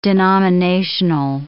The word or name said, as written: denominational